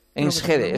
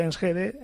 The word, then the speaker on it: Enschede: Enschede.